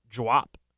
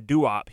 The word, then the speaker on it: Duop: It was pronounced Dwop, like one syllable. I can't even do it. Dwop. Dwop. Just one syllable. And because everyone just kept saying Duop, he was like, yeah, it's Duop.